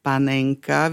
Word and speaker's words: panenka: to jemné kvalitné mäsko z dolnej časti chrbta ošípanej, tak to je definované, sa nazýva aj panenská, ale máme aj pomenovanie panenka. Vyslovujem to schválne tvrdo, pretože je to také, niekto to vyslovuje možno, že meko, niekto tvrdo, ale väčšina Slovákov to vyslovuje tvrdo, takže môžeme povedať, že sme si kúpili panenku, ale máme aj homonymum panenka, ktoré sa takisto občas vyslovuje aj tvrdo, ako panenka.